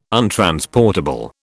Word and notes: untransportable: British English

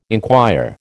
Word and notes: enquire: American English

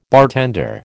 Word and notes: bartender: American English

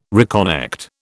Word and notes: reconnect: British English

